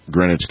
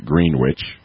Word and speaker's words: Greenwich: So, well, it's like the city Greenwich, Connecticut. It's spelled like, it looks like Greenwich, but it's pronounced Greenwich.